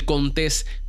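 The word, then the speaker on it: Contés: Mi nombre es Miguel Contes, con acento en la E, y este es el podcast donde nutres tu mente con ideas innovadoras.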